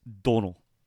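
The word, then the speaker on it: Domhnall: It's, by the way, I figured out how to pronounce his name. It's Donal.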